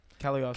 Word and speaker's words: Cagliostro: Cagliostro. – Cagliostro.